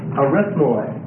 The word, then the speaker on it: Arithmoi: Arithmoi. That's spelled A-R-I-T-H-M-O-I. Arithmoi.